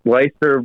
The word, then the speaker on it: Leicester: Chelsea versus Leicester. Is that how you pronounce that? – Leicester.